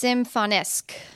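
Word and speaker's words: Symfonisk: Symphonisk. – Let me see it. – Symphonisk. – It's Symphonisque.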